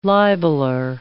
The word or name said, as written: libeler